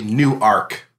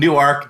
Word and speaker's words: Newark: No, it's actually Newark. No, that's how they pronounce it, in Newark, Delaware. And it's Newark, New Jersey. – Is it two words? – No, it's one word, but they pronounce it that way.